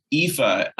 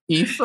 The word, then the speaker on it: Aoife: Yeah, Aoife. It's all right. Like, y'all would not believe how this is spelled. Aoife, and it's Aoife.